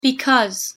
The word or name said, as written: because